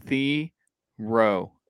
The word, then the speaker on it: Thiero: It's the pronunciation guide is Thiero, and it says in parentheses like hero.